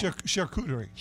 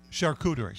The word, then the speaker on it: charcuterie: Well, how do you say that? – Charcuterie. – Charcuterie.